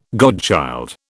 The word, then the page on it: godchild: British English